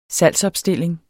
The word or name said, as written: salgsopstilling